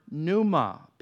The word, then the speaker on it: pneuma: and that's the word pneuma, P N E, in, in the way it's written, uh, P N E U M A. The P is silent, obviously, but that's uh, the word pneuma, uh, when we study the doctrine of the Holy Spirit, we study pneumatology, all right?